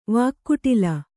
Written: ವಾಕ್ಕುಟಿಲ

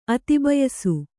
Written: ಅತಿಬಯಸು

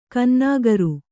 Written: ಕನ್ನಾಗರು

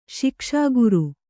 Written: ಶಿಕ್ಷಾಗುರು

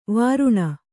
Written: ವಾರಣಿ

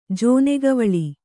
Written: ಜೋನೆಗವಳಿ

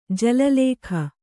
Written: ಜಲಲೇಖ

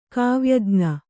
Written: ಕಾವ್ಯಜ್ಞ

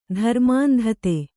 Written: ಧರ್ಮಾಂಧತೆ

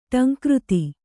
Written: ಢಂಕೃತಿ

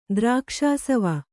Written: ದ್ರಾಕ್ಷಾಸವ

